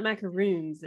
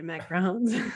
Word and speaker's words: macarons: They're not macaroons, they're macarons.